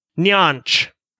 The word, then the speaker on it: Nyanch: She says that her name is Nyanch. – What? Nyanch? – Nyanch. – Nyanch. – How do you spell that? – N-Y-A-N-C-H. – Just like it sounds.